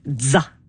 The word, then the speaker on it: zza: Comment on prononce ça, ZZA? – On l'appelle DZA. Comme la fin de pizza.